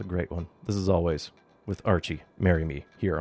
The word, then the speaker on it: Alvvays: They're known as Always, but spell it with double V's instead of a double U.